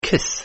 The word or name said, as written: kiss